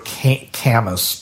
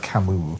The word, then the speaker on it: Camus: For you unlearned out there, it looks like Albert Camus, but it's pronounced Albert Camus.